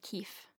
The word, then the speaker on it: Qiif: It's spelled Q-I-I-F, and we agreed that it was acceptable for me to pronounce it Keef.